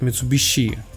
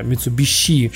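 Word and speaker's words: Mitsubishi: Да, потому что японцы всегда говорят что-то среднее между с и ш, они а говорят Митсубиши. Вот, это самое, вот щ, буква «щ» — это самое правильное произношение э, звука на русском языке.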